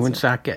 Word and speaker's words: Woonsocket: Is that how you pronounce Woonsocket. – It? – Unless you're from French Canada and you say Woonsocket.